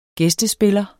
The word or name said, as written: gæstespiller